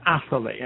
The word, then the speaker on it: Atherley: My last name is Atherley,